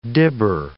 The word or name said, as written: dibber